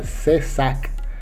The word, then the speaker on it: CISAC: Hay otro que se llama CISAC, en español se pronunciaría CESAC y ese es por invitación o por aceptación, mejor dicho.